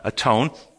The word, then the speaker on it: atone: Spelled A-T-O-N-E. But it's pronounced with the old English, just like when you're all by yourself, you're not all one, you're alone. We go and atone.